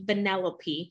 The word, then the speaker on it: Vanellope: And then I just looked up the character, and y'all, I butchered her name. Her name is actually Vanellope, and it's from the movie Wreck-It Ralph.